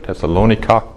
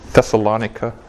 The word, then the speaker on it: Thessalonica: Also Thessalonich heißt es, sagen wir auf Deutsch, Thessalonica auf Englisch. Oder Thessalonica, wenn man das genau richtig aussprechen will.